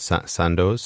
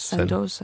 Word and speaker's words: Sandoz: I don't know how you say it. Sandoz? – Sandoz, I think, yeah.